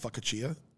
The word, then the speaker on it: focaccia: Also, I believe it's pronounced focaccia. – Fuck a chia, fuck a chia.